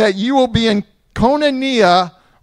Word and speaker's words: koinonia: In the Greek, the word is pronounced koinonia.